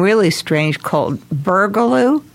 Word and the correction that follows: Burgoo: Yeah, we're saying Burgoo, B as in boy, U R. – Yeah. – G as in girl, O-O.